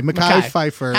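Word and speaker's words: Mekhi Phifer: And have a relationship with Mickey Pfeiffer. Mackay Pfeiffer. – How many podcasts can you mispronounce his name on? – I will find out in the film.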